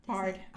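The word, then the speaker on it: Byard: Kevin Bayard. – Bard. – How many times am I going to get his name wrong? – I don't know. – Kevin Bard.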